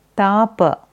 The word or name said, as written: Tapa